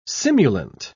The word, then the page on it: simulant: British English